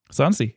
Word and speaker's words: Sansi: Sansi, yeah.